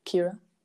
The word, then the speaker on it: Kira: On Instagram, Kira, my name, X, Aguilar, A-G-U-I-L-A-R. – Okay. And Kira K-I-R-A. – Is... K-I-R-A.